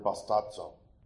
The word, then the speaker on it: bastazo: because the removal of the sandals is the word bastazo, which rhymes with the word baptizo.